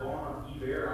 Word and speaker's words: Bon Iver: And so um, we might equate him to uh, people that we know by the name of uh, Bon, bon Iver, Bon Iver, I don't even know how to pronounce the name. – Uh, bon Iver,